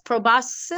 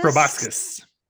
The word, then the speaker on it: proboscis: So I don't know how to pronounce this word, but it's P-R-O-B-O-S-C-I-S. Proboscis. – Proboscis.